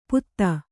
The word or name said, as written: ಪುತ್ತ